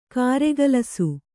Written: ಕಾರಗಲಿಸು

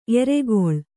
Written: ಏರುಗೋಲ್

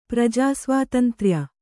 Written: ಪ್ರಜಾಸ್ವಾತಂತ್ರ್ಯ

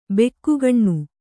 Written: ಭೊಗ್ಗನೆ

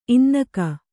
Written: ಇನ್ನಕ

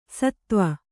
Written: ಷತ್ವ

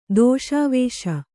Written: ದೋಷಾವೇಶ

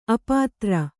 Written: ಅಪುತ್ರ

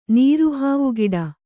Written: ನೀರುಹಾವುಗಿಡ

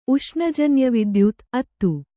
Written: ಉಷ್ಣಜನ್ಯವಿದ್ಯುತ್(ತ್ತು)

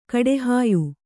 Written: ಕಟ್ಟೆಹಾಯ್

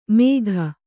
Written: ಮೇಘ

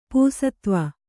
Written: ಪೂಸತ್ವ

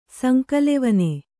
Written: ಸಂಕಲೆವನೆ

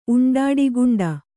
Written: ಉಂಡಾಡಿಗುಂಡ

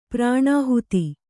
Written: ಪ್ರಣಿಹಿತ

